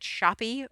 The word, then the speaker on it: Shoppe: I never know how to pronounce S-H-O-P-P-E. Is it Shoppy or I don't know. Anyway, whatever it is, it's called The Cut Shop. – I believe it's Shoppe.